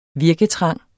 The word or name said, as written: virketrang